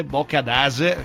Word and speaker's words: Boccadasse: Giovanni Juan Brichetto, genovese di mestiere, azionava il faro d'ingresso delle navi nel porto di Buenos Aires e fondò il Boccadasse, in genovese Boccadase, chiedo scusa agli amici genovesi perché chiaramente non è il mio accento, anche se siamo quella città.